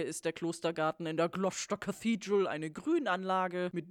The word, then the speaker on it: Gloucester: Drehort hierfür war die nicht Gloucester, sondern was haben wir gelernt, wie es ausgesprochen wird? Gloucester Cathedral.